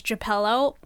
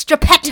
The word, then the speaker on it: Geppetto: And the older cousins were mispronouncing it as Geppello. And she would have a conniption and she'd be like, no, it's Geppetto.